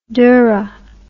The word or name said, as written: dhurra